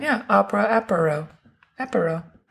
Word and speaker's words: apéro: So it's opera, and then apéro, which is A-P-E-R-O. – Opera apéro.